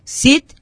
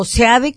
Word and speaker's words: Seade: vimos también a Jesús Cid o Seade, como lo han pronunciado algunos,